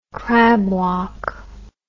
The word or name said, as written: crabwalk